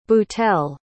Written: boutell

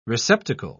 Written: receptacle

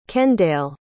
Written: kendale

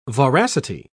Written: voracity

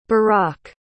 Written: barack

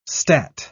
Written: stat

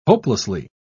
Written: hopelessly

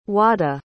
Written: wada

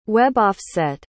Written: web-offset

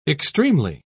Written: extremely

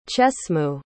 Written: chesmu

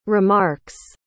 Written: remarks

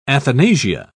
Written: athanasia

